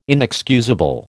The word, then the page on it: inexcusable: American English